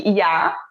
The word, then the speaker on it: ja: E IA é sim. Só que IA se escreve JA, porque J tem som de I no estoniano.